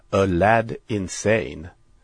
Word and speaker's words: Aladdin Sane: Och det namnet var egentligen en sorts travestering på namnet och skulle enligt David utläsas, som jag då flera år senare läste någonstans. Det skulle uttalas A Lad Insane. En grabb som är tokig alltså. Aladdin Sane, A Lad Insane.